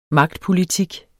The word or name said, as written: magtpolitik